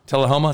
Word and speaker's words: Tullahoma: Actually, it's distilled at Dickel in Tuluma, Tennessee. This is a 20 year product. Tullahoma. Tullahoma is how you say it? Tullahoma. Uh, yes.